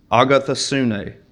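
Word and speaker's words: agathosune (ἀγαθωσύνη): And the Greek word for goodness is agathosune. It's five syllables.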